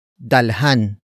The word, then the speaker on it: dalhan: Word for word, it's dalhan, mo, ako, ng, pagkain. Dalhan, mo, ako, ng, pagkain. Dalhan, mo, ako, ng, pagkain. The first word is dalhan. Dalhan. Dalhan. Dalhan is spelled as D-A-L-H-A-N.